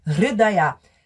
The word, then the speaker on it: Hridaya: Purna Hridaya, Hridaya, H-R-I, é um som que a gente no português não tem, Hridaya. Hridaya é coração em sânscrito. Purna Hridaya.